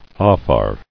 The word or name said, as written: Afar